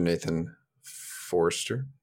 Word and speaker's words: Nathan Forster: Joining me today for the second time on this podcast is Mr. Nathan Forster.